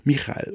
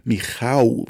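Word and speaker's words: Michał: Das da im Bett gegenüber ist SPC 1606-14, Michal oder Michau, wie sein Name wohl auf Polnisch ausgesprochen wird, ernährt seine Familie monatelang mit dem, was er hier tut.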